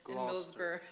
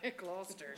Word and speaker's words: Gloucester: I say it wrong every time. Circle. – Gloucester. – Gloucester, thank you.